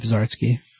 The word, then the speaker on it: Zarsky: Chip Zarsky. – Is that how you say it, though? – Yep. Si, senor. – It's drawn by... I don't even know how to say that. Sheedy? C-H-I-T-I? – Yep.